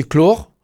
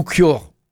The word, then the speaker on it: clore: Alors clore, on peut le prononcer clore ou cure, ça dépend des andrés, toujours pareil.